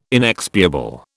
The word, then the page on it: inexpiable: British English